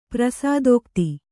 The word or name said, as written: ಪ್ರಸಾದೋಕ್ತಿ